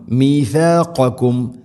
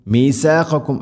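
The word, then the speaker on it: മീഥാഖകും (ميثاقكم): മീഥാഖകും മീസാഖകും എന്നല്ല, മീഥാ മീ സീനാവരുത്.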